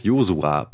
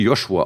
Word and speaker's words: Josua: PS, eine biblische Person heißt Josua. Bei ihm spricht man das S nicht als Sch aus. Ich denke, das ist viel klüger, als es wie Joshua auszusprechen und ich würde mich über die weitere Nutzung des Namens Josua freuen.